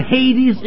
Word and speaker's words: Hades: It is spelled H-A-D-E-S, Hades.